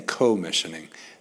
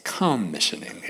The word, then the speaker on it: commissioning: All week long I've been saying co-missioning. There's a difference between co and com. Co is a little bit more side by side. Com is together, community, communion. So it's commissioning.